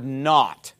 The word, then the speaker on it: naught: Take the Y off the end, and you're left with the word not. And that is a synonym of our word nothing. Not means nothing when it's spelled N-A-U-G-H-T.